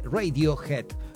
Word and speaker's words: Radiohead: o Radiohead, muchos en la Argentina, viste que formamos todo, pero es Radiohead, la banda británica que dejó canciones que marcaron distintas generaciones, desde Creep hasta Karma Police y Paranoid Android.